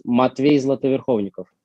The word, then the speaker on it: Matvey Zlatoverkhovnikov: Well, actually, my name in Russian, as it's supposed to be, sounds Matvey Zlatoverkhovnikov.